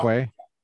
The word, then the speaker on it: qua: Is that how you say it in Latin? Qua instead of qua. Quay? – Yeah, qua.